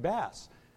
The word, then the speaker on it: Bass: It's Rick Bass. And so once you know that all three of those well-known writers are asses, you've got the pronunciation.